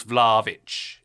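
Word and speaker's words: Vlahović: I think, and correct me if I'm wrong, I think you can go like two syllables Vlahovic if you say it quick enough.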